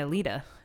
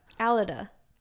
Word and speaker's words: Alida: Oh, that's how you say her name. – Yeah. – I was saying Alida. Ayo. She was on the board when I lived there. So yeah. That's okay. Say her name one more time. – Alida. – Alida. Wow. Would have never gotten there.